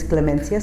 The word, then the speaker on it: Clemencia: My name is Clemencia Zapata. That's C-L-E-M-E-N-C-I-A. Last name Zapata, Z-A-P-A-T-A.